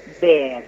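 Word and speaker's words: Bär: Sí, como vos bien mencionás, se pronuncia de manera distinta de cómo se escribe, porque es un nombre de ascendencia alemana. Entonces, en realidad, habría que pronunciarlo Ber. Pero bueno, sí. Yo ya lo castellanicé y entonces todo el mundo me conoce por Nora Bar, porque si no hay que estar explicando cada vez.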